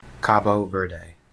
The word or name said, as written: Cabo Verde